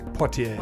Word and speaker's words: Poitier: Poitier.